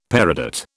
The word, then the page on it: peridot: British English